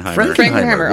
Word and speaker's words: Frankenheimer: Frankenheimer.